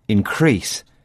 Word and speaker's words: increase: This time, the stress falls on the second syllable. Increase. Because Lucy's using the word as a verb.